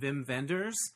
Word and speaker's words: Wim Wenders: My friend Jeffrey invited me to go to a Q&A with the documentarian Wim Wenders, even though it's spelled with a W, you say it like a V.